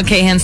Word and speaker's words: Kahan: Boston was just like Noah Kahan's stick season. And is that how you say his name? I always say Kahn, so it's Kahan.